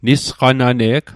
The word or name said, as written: nisqanane'g